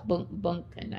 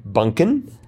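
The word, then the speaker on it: Bündchen: Bunchen, I think it's pronounced. Yeah. – Okay, Bunchen.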